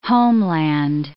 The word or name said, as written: homeland